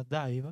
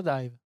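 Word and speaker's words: DIIV: se escribe d i i corta, pero se pronuncia Dive. Ah, eh, se pronuncia Dive, sí.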